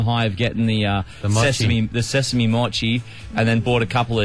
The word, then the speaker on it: mochi: That was mochi.